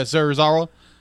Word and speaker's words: Serizawa: Serizawa? – Yeah, Serizawa. I said it right.